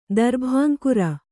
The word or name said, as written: ದರ್ಭಾಂಕುರ